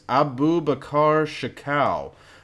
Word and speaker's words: Abubakar Shekau: This guy's name is Abubakar Shekau, and there's been a price on his head for quite a while.